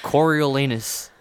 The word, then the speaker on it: Coriolanus: Coriolanus. How do you pronounce his name? – That's right. That's how you say it. That's literally how you say it. – You literally have to say anus.